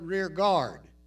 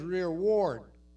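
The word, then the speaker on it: rearward: So many people right there pronounce that word rear guard, but it says rear ward.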